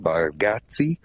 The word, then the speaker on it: Bargatze: His last name's Bargatze. Bargatze, I think is how you say it.